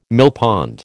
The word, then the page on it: millpond: American English